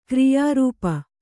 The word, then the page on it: ಕ್ರಿಯಾರೂಪ: kriyā rūpa